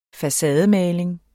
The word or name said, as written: facademaling